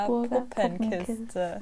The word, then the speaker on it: Augsburger Puppenkiste: Augsburger, Augsburger Puppenkiste.